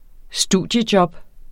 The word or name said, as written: studiejob